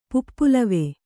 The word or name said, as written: ಪುಪ್ಪುಲವೆ